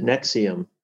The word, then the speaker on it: NXIVM: If I'm, if that's how yeah, they pronounce it Nexium. Nexium. Isn't that cool? – wow!